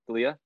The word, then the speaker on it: D'Elia: Like, for example, off the top of my head, and I don't even know the whole situation, but like Chris D'Elia, did he say his name? D'Elia.